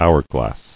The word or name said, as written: Hourglass